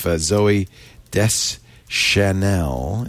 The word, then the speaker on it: Zooey Deschanel: Let me just see if Zooey Deschanel...